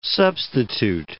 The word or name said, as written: substitute